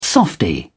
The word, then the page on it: softie: British English